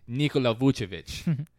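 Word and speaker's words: Nikola Vučević: but it's Nikola Vucevic. You got to add the accent in there. It's Nikola, not Vooch.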